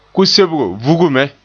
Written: vuge

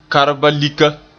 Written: kaarbalika

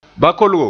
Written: bakolgo

